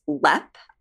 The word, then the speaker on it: Leap: Apparently, it is pronounced lep, I have read, but it's spelled like leap.